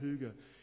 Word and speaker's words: hygge: H-Y-Y-G-E, I think, but you say it hygge.